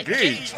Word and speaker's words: Cage: Nicolas Cage se dice, es que se escribe cagué, pero se pronuncia Cage.